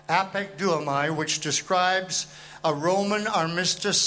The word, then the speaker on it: apekduomai: The word used there as that verb is the word apekduomai. And I'm going to have to spell it for you so you'll understand what I'm saying. Apekduomai is spelled A-P-E-K-D-U-O-M-A-I.